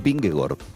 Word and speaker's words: Vingegaard: La doble A en los idiomas escandinavos se pronuncia como una O. Es decir, el ciclista danés Vingegaard es Vingegaard, por raro que suene.